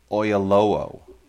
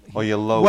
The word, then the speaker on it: Oyelowo: David Oyelowo. Oyelowo.